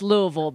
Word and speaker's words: Louisville: Well, it's Louisville, because anywhere that's got a vol, which is a lot of places, especially in Tennessee, we've got like a Nashville, Knoxville, Shebville.